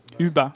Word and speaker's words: Hubain: Comment il s'écrit H-U-B-A-I-N. – Ubin. – Ubin. C'est comme quand on est un peu enrhumé, quoi. – C'est une série française ou internationale? – Non, c'est une série internationale. Et pour les gourmands. – Ubin.